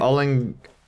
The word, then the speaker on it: olinguito: How do you say olinguito.